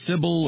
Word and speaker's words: Cybele: Some would say Sybil or whatever, but the pronunciation given by Wikipedia was Cybele.